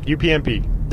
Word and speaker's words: UPnP: allow you to grab that from like a Sonos or anything that would grab from a UPnP, is that how you say it? – UPnP.